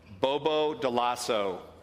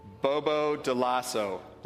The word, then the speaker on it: Bobo Dioulasso: Bobo Dioulasso. Ready? Bobo Dioulasso.